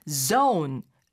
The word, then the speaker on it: zone: 어떤 영역이란 뜻으로 쓰게 되긴 하는데 그러나 zone, zone 이렇게 발음해야 상대가 알아들을 수 있다고 합니다. – Right, zone. – 네. – Z-O-N-E, 그렇죠.